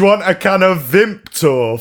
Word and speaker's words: Vimto: Vimto.